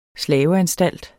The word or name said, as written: slaveanstalt